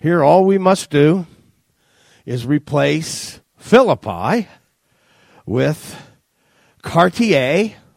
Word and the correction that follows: Chartiers: oh, that's Chartiers, I was pronouncing it in the French, or Houston, Pennsylvania.